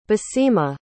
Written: basima

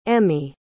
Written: emi

